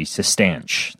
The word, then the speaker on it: Cistanche: So it's Cistanche, Sistanche. That's how you pronounce it? – Yeah.